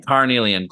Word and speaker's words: Carnelian: So it's good to know that Cornelian, right? That's how you say it? – Carnelian.